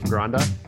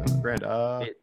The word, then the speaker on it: Granda: It's pronounced Joseph Granda, right? – It's Granda, yeah. Granda. It's Grand with an A at the end. – Granda.